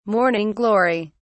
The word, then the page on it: morning glory: British English